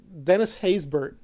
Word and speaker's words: Dennis Haysbert: And this is with the Dennis Haysbert.